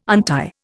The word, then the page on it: untie: Indian